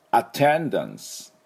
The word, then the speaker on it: attendance: で、出席はですね、なんていうかっていうと、 attendance、 attendance というふうに言います、ね。で、最初の「あ」はですね、ではなく「あ」なんです。で、 attendance で、この「 ten」のところにアクセントがあります。